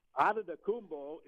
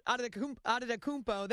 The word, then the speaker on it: Adetokounmpo: The Greeks added the T's and the K's and all of that and made it a little difficult. So Adetokounmpo is pretty phonetically easy with the Nigerian spelling.